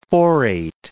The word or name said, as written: phorate